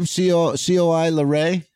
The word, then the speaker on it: Coi Leray: How do you say her name? COI Leray. You know about her? – Coi Leray. – Is that how you say it? Hoy.